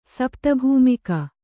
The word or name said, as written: ಸಪ್ತಭೂಮಿಕ